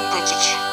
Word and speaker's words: Пытич: Фамилия его была Смыков, поэтому ударение на «И» Пытич.